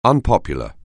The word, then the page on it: unpopular: anglais